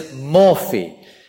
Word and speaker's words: morphe: S-C-H-E-M-A, schema. The other word is morphe, M-O-R-P-H, long E, again with a little line on the top to show it's long.